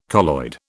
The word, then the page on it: colloid: British English